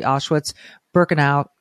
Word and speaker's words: Auschwitz-Birkenau: January 27th commemorates the liberation of the Auschwitz-Birkenau. Am I saying that right? – Yes. Yeah.